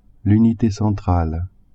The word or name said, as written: unite centrale